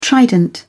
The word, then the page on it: trident: British English